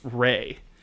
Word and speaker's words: Ra: But I learned in looking up this stuff for this fact that Ra is actually pronounced Ray. And that we've all just learned it wrong.